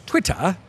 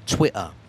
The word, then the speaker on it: Twitter: Twitter. – But Twitter. – Or some Londoners would say Twitter. – Twitter. – They won't pronounce the T's in the middle there.